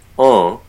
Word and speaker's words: Jean: Is a name where, you know, it's written like Jean, J-E-A-N, pronounced en with a nasalized vowel in French. So when you bring that into English, people frequently will say Jean as a way of anglicizing that pronunciation. But typically people go, oh, that's not quite right. And so they try to make a nasalized sound.